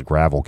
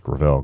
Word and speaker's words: Gravel: The hashtag that they came up with is I thought it was Gravel Gang, but apparently it's pronounced Gravel.